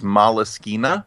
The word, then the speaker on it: Moleskine: But it was the way they spelled it out phonetically was Moleskine, and that's how I've been pronouncing it ever since.